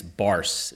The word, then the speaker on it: Barse: With me today, I have special guest Vance Barse.